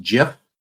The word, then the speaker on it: GIF: It's pronounced GIF. Did you know that? Like the peanut butter. That's how it's supposed to be.